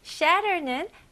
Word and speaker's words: shatter: 네, 대부분의 이제 미국식 발음으로 중간에 이렇게 TT가 있으면 이렇게 가벼운 D 발음이 주로 나와요. 예를 들어서 better, 그쵸? butter, okay? matter 이런 식으로 똑같이 여기서 shatter보다는 shatter 해볼게요. shatter, shatter. Very good.